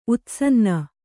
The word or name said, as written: ಉತ್ಸನ್ನ